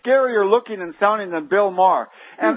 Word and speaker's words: Maher: It was on the Bill Maher show, right? – Yes.